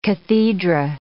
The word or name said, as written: cathedra